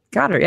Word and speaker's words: Goedert: Dallas Goddard. – Goddard, yeah, Dallas Goddard, but yeah, yeah, yeah, That's what, yeah, I that's what said.